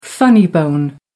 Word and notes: funny bone: American English

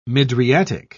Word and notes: mydriatic: British English

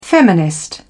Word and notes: feminist: British English

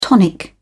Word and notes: tonic: British English

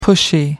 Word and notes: pushy: American English

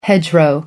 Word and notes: hedgerow: American English

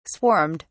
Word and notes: swarmed: British English

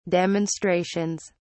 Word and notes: demonstrations: British English

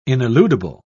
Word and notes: ineludible: British English

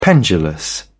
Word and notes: pendulous: British English

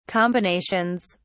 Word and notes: combinations: British English

